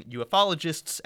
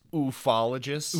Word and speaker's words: ufologists: And Point Pleasant, from that book, became very famous among ufologists and cryptid hunters and other fans of the paranormal. – It's pronounced ufologists. – Ufologists, I'm sorry.